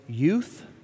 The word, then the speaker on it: Uth: For those who do not know me, my name is Jarian Uth, U-T-H.